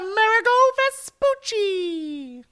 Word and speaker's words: Amerigo Vespucci: pizza pie was discovered by Amerigo Vespucci. – Oh, that was nice pronunciation. – Amerigo Vespucci.